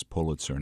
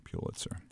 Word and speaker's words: Pulitzer: But it is Pulitzer, not Pulitzer.